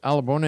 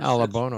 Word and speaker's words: Alabone: Exo Nation, our guest this hour is Richard ALABONE. And Richard, am I pronouncing your family name correctly? – Yeah, ALABONE, yes. – ALABONE, okay.